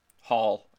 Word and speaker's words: Holl: Not to take away from Dermott or Hall, but... – Hall. It's Hall. It's pronounced Hall, but it's spelled H-O-L-L.